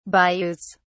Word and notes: bayous: British English

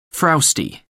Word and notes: frowsty: American English

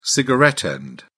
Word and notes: cigarette butt: British English

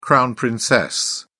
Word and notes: crown-princess: British English

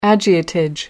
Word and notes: agiotage: American English

